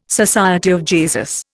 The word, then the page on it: SJ: Indian